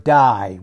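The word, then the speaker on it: die (δεῖ): That word ought in this particular place is the Greek word die. We pronounce, and it's D-E-I, die. But that's, we pronounce it like our English word D-I-E, but it's die, we ought.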